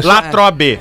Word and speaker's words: Latrobe: é Latrobe.